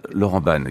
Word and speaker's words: Laurent Ban: And joining us this morning, very excited to have French musical singer and actor Laurent Bong. Did I pronounce that right? – Uh, Laurent Ban again said Laurent Ban.